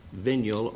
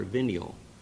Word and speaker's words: venial: Looks like the Nile. If you put a D in front of it, it would be denial, but according to the dictionary, it's pronounced venial or venial.